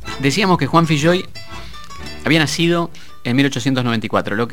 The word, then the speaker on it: Filloy: Se escribe Filloy, F-I-L-L-O-Y, y se pronuncia Filloy. Y él siempre decía, se pronuncia Filloy porque es gallego, no es irlandés. Esto es lo primero que hay que saber. Juan Filloy. Así, con esa shh.